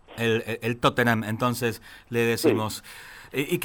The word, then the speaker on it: Tottenham: Así lo decía yo cuando yo llegué, pero yo decía Tottenham, o sea, pronunciaba la H, Tottenham, digamos. – Claro. – Y no se pronuncia, cuando la H está en el medio se dice Tottenham directamente.